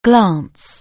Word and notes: glance: anglais

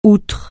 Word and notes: outre: francais

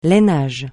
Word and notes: lainage: francais

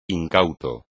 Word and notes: incauto: espagnol

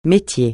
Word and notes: métier: francais

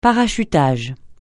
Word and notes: parachutage: francais

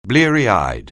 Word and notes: bleary-eyed: anglais

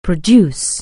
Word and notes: produce: anglais

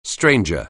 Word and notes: stranger: anglais

